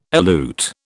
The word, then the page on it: elute: British English